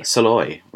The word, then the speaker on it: Szalay: All That Man Is by David Saloy. – Saloy, right. – So it's spelt S-Z-A-L-A-Y.